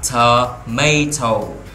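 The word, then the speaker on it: tomato: Thì chúng ta sẽ đọc là thờ mây thâu, thờ mây thâu, thờ mây thâu.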